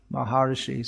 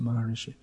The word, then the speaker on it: Maharshi: Mahesh Yogi Maharshi, something like that. Maharishi. It's actually pronounced Maharshi, but they pronounce it Maharishi.